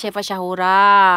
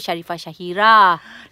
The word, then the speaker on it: Sharifah Syahira: Nama saya Sharifah Syahora. Eh. Nama orang lagi. orang selalu confuse. Sharifah Syahora. Saya cakap saya bukan Sharifah Syahora. Saya Sharifah Syahira. Taulah ejaan hmm. kau tu macam dekat-dekat.